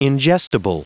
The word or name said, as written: ingestible